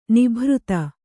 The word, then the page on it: ನಿಭೃತ: Kannada; nibhřta